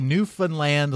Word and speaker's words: Newfoundland: It's apparently Newfoundland, like understand, is the fun memory device that you can use to remember how this name is pronounced.